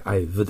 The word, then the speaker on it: I've: 一応 I have just finished の have は本当はあんまり発音しないので I've です。ただ形として have があるから書いただけで I've です。